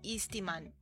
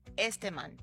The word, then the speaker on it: Esteman: ¿cómo se pronuncia? ¿Esteman o Eastman? Esteman. Esteman es como este hombre.